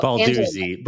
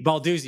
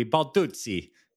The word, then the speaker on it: Balduzzi: Well, Balduzzi. Balduzzi. But if you want to get Italian, it could be Balduzzi.